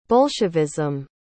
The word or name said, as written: bolshevism